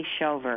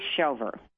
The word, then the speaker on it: Schover: Leslie, S-C-H-O-V-E-R. – S-C? – C as in cat, H-O-V-E-R, Leslie Shover, I think it would be pronounced.